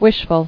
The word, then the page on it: Wishful: wish·ful